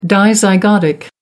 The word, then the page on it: dizygotic: American English